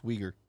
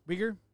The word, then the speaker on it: Uyghur: Yeah, it's pronounced Uyghur. – Uyghur? – Yeah.